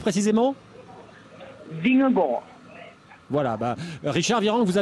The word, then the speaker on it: Vingegaard: C'est pas Vingegaard, c'est Vingegaard je, vais vous apprendre à prononcer son nom correctement. Vingegaard.